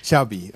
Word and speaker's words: Xavi: Xavi. – Okay. Yeah, Xavi like chocolate.